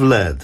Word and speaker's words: lead: Asleep at the wheel, pencil full of lead. Słusznie mnie Wojtek skorygował, bo choć pisze się tak, jakby można było lead przeczytać, to przecież to nie o to chodzi.